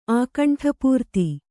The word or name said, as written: ಆಕಂಠಪೂರ್ತಿ